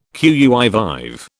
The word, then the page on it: qui vive: British English